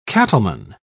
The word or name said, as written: cattleman